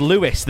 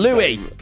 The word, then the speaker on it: Louis: Did you think one of the One Direction members was called Lewis then? – No. What's Louis. – Louis. – Lewis. – Sorry, Louis. Louis.